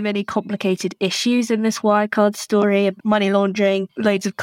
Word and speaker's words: Wirecard: The documentary is called Scandal, Bringing Down Wirecard. And yes, the German pronunciation is deliberate. It is spelt that way.